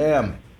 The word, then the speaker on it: Sam: Sam.